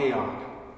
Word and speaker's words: Aeon: There's a great article on Aeon. I hope I'm saying that right, Jay. Am I saying it right? I did it. Okay, good.